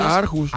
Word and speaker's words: Aarhus: Y se pronuncia Aarhus.